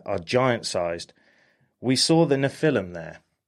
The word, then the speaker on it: Nephilim: So the book of Genesis 6:4 tells stories of the Nephilim. I think that's how you say it. Nephilim? – Nephilim.